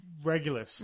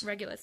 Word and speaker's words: Regulus: Regulus. – Regulus, thank you.